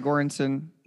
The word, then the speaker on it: Göransson: How do you pronounce his last name? – I think Göransson. – Yeah, Ludwig Göransson.